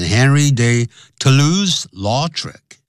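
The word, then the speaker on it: Henri de Toulouse-Lautrec: 好，通常呢，就是直接也就会念成 Henry de Toulouse Lautrec, 这是英文的念法。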